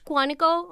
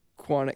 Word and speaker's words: Quantico: Quantico. – Oh, the television show. – Quantico? – Quantico. Yeah. – Quantico, okay. – No. – My pronunciation is weird. – No, you're good, you're good.